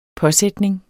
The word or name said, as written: påsætning